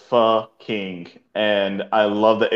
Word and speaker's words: pho: By the way, is it pho or pho, by the way? – How do you pronounce that word? Because somebody was in the comments saying we were pronouncing it wrong all night. – Oh, really? Oh, it's pho. – Fuh.